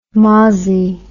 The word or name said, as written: mozzy